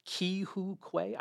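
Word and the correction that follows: Ke Huy Quan: I always get his name wrong. – I've been hearing Ke-Hee Kwan. – Ke-Hee Kwan. Thank you.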